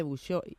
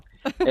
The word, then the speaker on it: Bouchot: Pues César, yo les estaba contando a los oyentes que no soy excesivamente mejillonera, pero este mejillón pequeñito, el mejillón de Bouchot, y disculpa mi pronunciación, que seguramente es nefasta, pero yo lo digo así, tú los traes, entiendo, de la Bretaña francesa, ¿verdad? – Sí, además lo has dicho fenomenal.